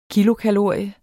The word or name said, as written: KILOKALORIE